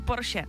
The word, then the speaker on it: Porsche: A pak tady ještě Porsche, jo. To my Češi říkáme skoro ze všech jako národů jediný správně. Všichni říkají Porsche špatně, ale je to opravdu Porsche.